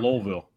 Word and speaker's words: Louisville: Louisville. You got to say it like you have a dick in the back of your throat. – Louisville. It's Louisville.